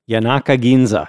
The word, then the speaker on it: Yanaka Ginza: Yanaka Ginza. – Oh, that's how you say it.